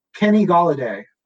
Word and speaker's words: Golladay: His name rhymes with holiday. Kenny Golladay, over under 1,199.5 receiving yards.